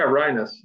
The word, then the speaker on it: Rienas: Yeah, Rienas.